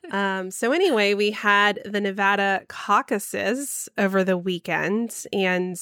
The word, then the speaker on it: Nevada: It's Nevada, not Nevada.